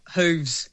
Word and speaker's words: hooves: Is it pronounced hoofs or hoofs? – You're the farm girl. – Really going to hooves. – Hooves. – Okay. With a V.